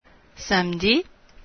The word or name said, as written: samedi